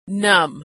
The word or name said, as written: numb